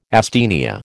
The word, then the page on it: asthenia: American English